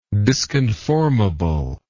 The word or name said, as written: disconformable